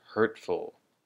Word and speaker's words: hurtful: And the next word, I think, which often gets mixed up with the word painful, is the word hurtful. Hurtful. The emphasis on both these words is on the first syllable.